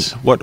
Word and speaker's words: Yaz: Yaz. What's Yaz? – Well, it's Jazz with a silent J.